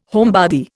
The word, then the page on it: homebody: Indian